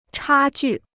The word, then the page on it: 差距: chā jù